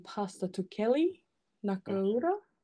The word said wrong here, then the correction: Nakauta: Oh, Nakauta, yes.